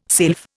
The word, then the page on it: sylph: Indian